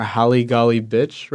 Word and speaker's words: Holly Golly Bitch: Ibuprofen w- was a song that I enjoyed like as a single, but then the transition into pronounce uh, correct me if I pronounce this wrong, Holly Golly Bitch, right? Yes, Holly Golly yes.